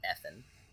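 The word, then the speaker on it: Effen: Uh, effin vodka is is how you say it.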